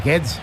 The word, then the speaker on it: Guedes: Y el más sorprendente, ahora lo comentaremos, fue el de Gonzalo Guedes, porque no lo vais a poder pronunciar. – Es Guedes.